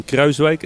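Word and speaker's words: Kruijswijk: Stephen Kruiswijk, sorry, the pronunciation please, Dan. – Stephen Kruiswijk, as we say in Holland. – Stephen Kruiswijk.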